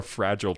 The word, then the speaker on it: fragile: The glass, we're a fragile people. – Fragile. – Fragile is the same. He's fine. – No, that's the American pronunciation. – Oh my God. – It's fragile in the rest of the world.